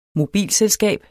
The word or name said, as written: mobilselskab